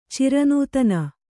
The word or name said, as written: ಚಿರನೂತನ